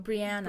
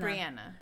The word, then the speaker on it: Brianna: Claire pronounces it Brianna. – Brianna.